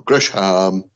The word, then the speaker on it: Gresham: Jonathan Griesman, or Griesham, am I pronouncing that right? – Gresham.